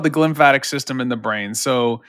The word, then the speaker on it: glymphatic: Can you explain, firstly, am I pronouncing that right? And secondly, what the hell it is and how it works? – Yeah, you're talking about the glymphatic system.